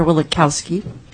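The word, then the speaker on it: Wielechowski: Through the chair, Senator Wilikowski. Did I say it right? – Yes, you did.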